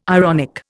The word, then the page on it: ironic: Indian